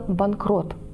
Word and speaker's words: банкрот: Так, например, слово «банкрот» было заимствовано в 18 веке из голландского языка и первоначально в русском языке звучало как «банкрут». Аналогичное произношение имели и его производные – «банкрутство» и «обанкрутиться». Во времена Пушкина появляется произносительный вариант с буквой «о». Можно было говорить «банкрут» и «банкрот». К концу XIX века окончательно победило «банкрот» – «банкротство» и «обанкротиться».